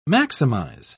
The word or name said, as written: maximize